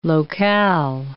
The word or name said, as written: locale